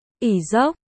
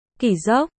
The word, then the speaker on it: Kỷ Dốc: Đây là một kế trong binh pháp mọi người hay được nghe hoặc đọc là Ỷ Dốc, nhưng nguyên bản nó phải là Kỷ Dốc, chỉ hành động tách binh lực ra làm hai phía để kìm hãm quân địch hoặc để chi viện cho quân mình.